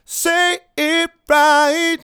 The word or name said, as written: Say It Right